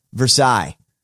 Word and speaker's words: Versailles: That's how cities are pronounced in the South. Versailles. – Oh, how do you think? – Yeah. – Versailles. It's not Versailles. It's Versailles.